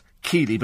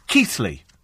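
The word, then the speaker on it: Keighley: Some people pronounce it Keely, but in fact it's Keithley.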